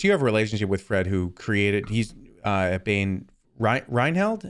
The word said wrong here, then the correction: Reichheld: am I saying that right? – Reichheld. – Reichheld.